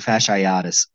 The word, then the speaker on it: fasciitis: I hate to say it, but yeah, I hope Damian Lillard gets like plantar fasciitis. Yeah. Fasciitis. – Wrong emphasis on the wrong syllable.